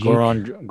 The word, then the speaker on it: Goran: And in the return, the Raptors get Doran Dragic. Am I saying that right? Dragic. – Goran, Gor- Goran, Dragic.